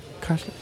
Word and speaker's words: Kreisler: Klopt dat wel, die uitspraak? Kreisler? – Waanzinnig.